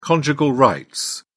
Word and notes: conjugal rights: British English

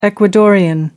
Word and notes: Ecuadorian: American English

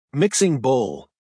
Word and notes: mixing bowl: American English

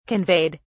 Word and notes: conveyed: British English

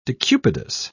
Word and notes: decubitus: British English

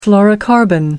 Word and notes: fluorocarbon: American English

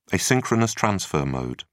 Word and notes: asynchronous transfer mode: British English

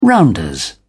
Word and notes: rounders: British English

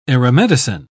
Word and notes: aeromedicine: British English

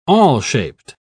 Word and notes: awl-shaped: British English

